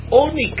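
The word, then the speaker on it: Oni (עני): Says Dovor Acher, another thing about Lechem Oni Kasiv. In the Torah, although it's pronounced Oni, it is written Ayin Nun Yud, and Ayin Nun Yud is Oni.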